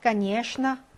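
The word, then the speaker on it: 까니에슈나 (конечно): 3번 까니에슈나. 자, 발음 이렇게 되고요.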